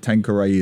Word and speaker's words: Tanqueray: You have to explain to me what Tanqueray is. Am I saying it correctly? – Yes, it's a brand of gin.